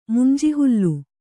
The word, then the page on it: ಮುಂಜಿಹುಲ್ಲು: Kannada